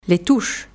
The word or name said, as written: les touches